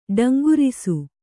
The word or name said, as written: ಠೇಂಕರಿಸು